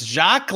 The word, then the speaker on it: Jacques: It's Jacques, like sounds like Shaq.